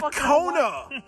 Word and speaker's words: Kona: You're drinking a Kahuna. – Fuck, it's not a Kahuna. – You don't know how to pronounce it. It's a Kona.